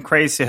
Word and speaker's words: crazy: Ja, jag eh, gillar att uttala eh, Z med tonande S. Yes. Yes. Jag är med. Yes. Och eh, ja, jag har hört dig nämna lite eh, om crazy humor i olika sammanhang.